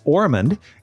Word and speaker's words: Ormond: It's C-E-V-I-N. Ormond is just like Osmond, only with an R instead of an S. So it's O-R-M-O-N-D.